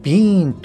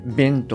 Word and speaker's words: been: で、さらにですね、ビーンもですね、ビーンとは発音せずに、短くですね、ビンとかペンとか、はい、あの、こんな発音になります。